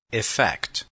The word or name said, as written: effect